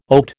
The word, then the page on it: oat: American English